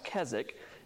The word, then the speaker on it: Keswick: So it's pronounced the Keswick view. That W is silent, spelled Keswick, but pronounced Keswick, named after a town in the English Lake District, if you've ever been to England.